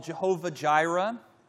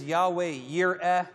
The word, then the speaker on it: Yahweh Yireh: And today we're going to talk about the name commonly called Jehovah Jireh. The correct pronunciation is Yahweh Yireh.